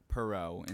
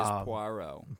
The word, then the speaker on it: Poirot: Everyone is calling him Perot in the movie. Everyone is pronouncing it incorrectly. – Yeah. Which that's what got me confused because like we'll get into it. – It is Poirot. – Poirot. – Like P-W-A. R-O-W.